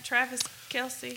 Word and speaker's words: Travis Kelce: Travis Kelce. – Did I say that right? – Okay. Yeah.